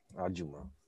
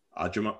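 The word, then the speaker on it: ajumma (아줌마): How do you pronounce that? – Ajumma. – Ajumma. Oh, ajumma. – Ajumma.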